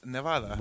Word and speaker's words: Nevada: Nevada. That's how people from Nevada say it, but in Spanish, it's Nevada.